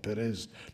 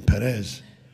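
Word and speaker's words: Peres: He didn't say Perez, but Perez, like a Spanish pronunciation, Perez.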